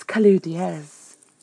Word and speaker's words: Kalu Diaz: It's Kalu Diaz.